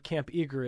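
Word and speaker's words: Camp EAGR: And let's just start with the basic description of what Camp Eager is, and again, if you're hearing it, you're not seeing the way it's spelled, E-A-G-R.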